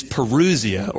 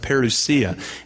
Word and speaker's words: parousia: The Greek word is parousia, or sometimes pronounced parousia.